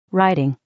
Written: riding

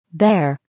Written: bear

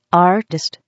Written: artist

